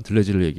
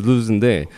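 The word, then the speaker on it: Deleuze: 들레지를 얘기해도 못 알아듣고 일단 발음부터 아니죠. 들레지가 아니니까. 예, 그러니까 들루즈인데 예. 토 발음이 어떻게 됩니까? 들루즈요. – 들루즈. 예, 들루즈. 아~ 예, 이렇게 되는데 그, 근데 그것도 하기 힘들어요.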